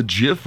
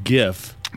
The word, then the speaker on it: Jif: Well, the Jif recall now includes products from 18 companies. If you remember, it's pronounced GIF. – It's not. – No. – It's called Jif or Jiffy.